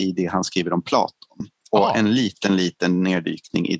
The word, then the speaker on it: Platon: Pelaton, är det Platons brorsa? Pelaton. – Uttalade, sa jag så? – Du sa Pelaton. – Platon, mattan. Ja.